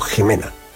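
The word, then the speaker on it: Ximena: Jimena, hay muchas personas que tienen este nombre y que lo escriben con X, pero lo pronunciamos o se debe pronunciar como Jimena, o también el apellido Jiménez.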